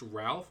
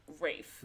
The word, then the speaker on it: Ralph: Is it pronounced Ralph or Rafe? Oh, it's pronounced like Rafe. – Rafe, sorry. Like Rafe, it's spelled Fiennes, like Ralph. Voldemort. Yeah. – Yes. Yeah, so it's pronounced Rafe.